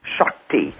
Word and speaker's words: Shakti: Shakti, S-K-A-T-I, Shakti. – You mean S-H? – Pardon me? – Shakti. – Yes, but we would spell it S-C-H-O-C-K-T-I, just as in a shock, Shakti.